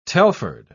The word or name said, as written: telford